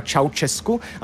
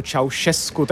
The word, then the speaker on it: Ceaușescu: tak jsem vyslovoval celou dobu jméno rumunského diktátora Ceaușescu a byl jsem opraven správně, že je to Ceaușescu,